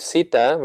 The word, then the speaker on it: Sita: Sita? – Sita. – It's a long I? – Yeah.